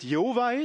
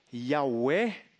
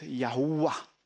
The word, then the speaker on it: Yahweh: Ik geloof niet dat het Jehovah is, want Jehovah is in het middeneeuwen ontstaan. Ik geloof dat de uitspraak meer in de zin van Yahweh. Maar met een kleine nuance, ik geloof dat het Yahuwah.